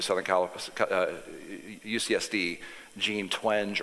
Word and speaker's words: Twenge: It's Twangy. I asked her how to pronounce her name. Okay. She told me Twangy. – All right, I'll take it. – Twangy, just like Twangy. From the South.